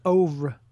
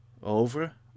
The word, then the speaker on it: oeuvre: I think it's oeuvre. – Oeuvre? – Oeuvre, yeah. Okay. Like genre.